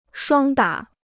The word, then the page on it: 双打: shuāng dǎ